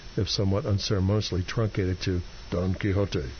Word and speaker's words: Don Quixote: Pronounced Don Quixote. – But you give a little key, hyphen, H-O. Okay.